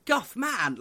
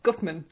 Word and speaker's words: Guffman: No, no, you say it like Guffman, like he's like a superhero. – Like a superhero. – Okay. – Yeah. – Not Guffman, like Batman.